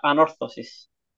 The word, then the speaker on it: Anorthosis: So, you were close enough for how is it said properly? – Anorthosis. – Anorthosis. Okay, so it's the second. Yeah. So it's the second syllable that gets that.